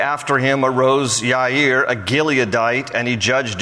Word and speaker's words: Yair: And then number 7 is Yair. There's no J in the Hebrew alphabet, so his name is really spelled with a Y in Hebrew, Yair.